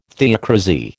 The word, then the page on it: theocrasy: American English